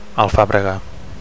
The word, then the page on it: alfabrega: Catalan